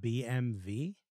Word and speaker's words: BMW: You know, BMW is pronounced, it's pronounced BMV.